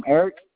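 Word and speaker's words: Erick: It's Eric, ERIC-K.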